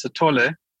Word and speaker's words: Sithole: With this guy, his pronunciation is Moses Sithole, and he's definitely one of the, I guess, as you call it, you always say the rock stars of serial killers.